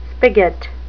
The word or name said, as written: spigot